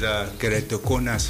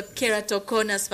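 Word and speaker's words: Keratoconus: But before we get into our main questions, how do you pronounce this, the name of the foundation? – The name is Keratokonas Foundation. – Okay, so Keratokonas Foundation. – Yes, please.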